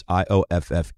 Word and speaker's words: Ioffe: Julia Ioffe, I think is the way you pronounce the last name. It is Ioffe.